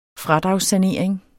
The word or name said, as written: fradragssanering